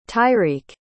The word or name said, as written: tyreak